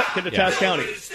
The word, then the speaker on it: Kittitas: Kittitas, am I pronouncing that correctly? – Yep, Kittitas County.